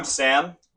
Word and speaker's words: Sam: I'm Sam. I sing, and it's spelled S-A-M. – I'm Prince. It's P-R-I-N-C-E.